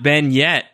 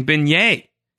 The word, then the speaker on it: beignet: Uh, I also like how she says it's not Ben yet, and I'm like, Juliet, you're mispronouncing beignet.